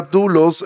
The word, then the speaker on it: doulos: Siempre que tú ves la palabra dulos, eh, siervo, perdón, es dulos. En el Nuevo Testamento se escribe doulos que significa esclavo.